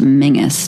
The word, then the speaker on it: Menzies: his last name is actually pronounced Mingus, Tobias Mingus, but it looks like Menzies.